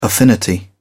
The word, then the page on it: affinity: British English